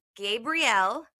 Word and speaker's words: Gabrielle: So we are here with Gabrielle.